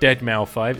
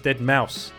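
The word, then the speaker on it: deadmau5: It's not Dead Mouse 5, it's Dead Mouse, I've been informed by today's guest, go check it out.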